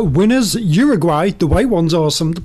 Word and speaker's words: Uruguay: Sorry, Mark. Uruguay. Thank you for correcting my pronunciation.